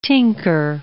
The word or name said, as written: tinker